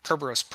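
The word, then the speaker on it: Kerberoast: So similar to curb roast is uh, the Kerberos curb roast pre-auth, also known as the AS rep roast. But that's how I say. It. Is that is that how you say it, Jim? – Yep.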